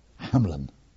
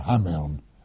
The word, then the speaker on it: Hameln: No, this was in the famous place called Hameln, or Hameln, as it's spelt, H-A-M-E-L-N, where Irma Grease had her trial.